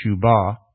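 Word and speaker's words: shuwbah: In Hebrew, the word is shubah. If you want to take notes, it's S-H-U-W-B-A-H. You pronounce it shubah.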